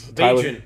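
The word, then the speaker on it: Bagent: Bajent. – Bajent, yeah. – Rhymes with agent.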